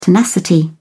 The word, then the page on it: tenacity: British English